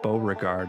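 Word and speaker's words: Bowregard: The band is called Beauregard, and that's spelled B-O-W, like a violin bow, and then regard, all as one word.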